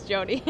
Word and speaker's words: Jody: Does her name rhyme with Mindy? – Nope. She's Jody. – Jody. – The well, D-Y part at the end.